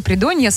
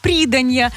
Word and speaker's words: придонья: Как-то молодой диджей прочитал в эфире вместо сады придонья, сады приданья. А вместо елка на пенечке, елка на пенечке. А все потому, что я не проставила ударение, ну и чья-то невнимательность.